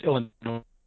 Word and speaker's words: Illinois: I think it's pronounced Illinois. – I hate Illinois Nazis. – The S is silent.